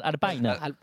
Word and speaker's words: Alpine: Il faut le prononcer à l'anglaise, Alpine.